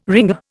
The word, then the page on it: ringer: Indian